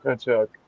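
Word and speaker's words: Tkachuk: There's no T. It's just Kachuk. – There's a T right there. Oh, I see what you mean. The T is silent.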